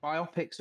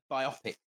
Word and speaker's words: biopic: I'm in the biopic team, like a biography or a biopsy. I don't think it rhymes with myopic is my issue.